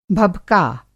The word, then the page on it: भबका: Hindi